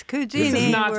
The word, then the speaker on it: Cugini: It's Cugini. – We're not speaking Spanish here. It's not Cugini. It's Cugini.